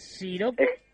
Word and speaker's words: Shiroco: ¿Siroco es Siroco? – Exacto.